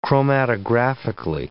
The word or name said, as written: chromatographically